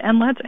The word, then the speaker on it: NLETS: Well, so there's a national data sharing system, which is called NLETS, or it's an acronym, but people pronounce it NLETS, N-L-E-T-S.